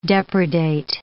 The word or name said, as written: depredate